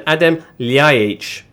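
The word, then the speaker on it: Adem Ljajić: Serbian signings, Zoran Tosic and Adem Ljajic.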